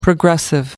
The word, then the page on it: progressive: American English